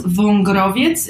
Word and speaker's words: Wągrowiec: It's pronounced Wągrowiec.